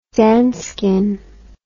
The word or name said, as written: danskin